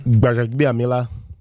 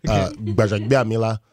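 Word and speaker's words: Gbaja-Biamila: So what's the Nigerian pronunciation? – Baja Biamila. – Yeah, you have to have lips for it. Baja Biamila.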